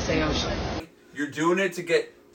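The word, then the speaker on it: Saosin: Seosin was like the way to pronounce it. And then everybody in the band since called it Seosin. It's Seosin.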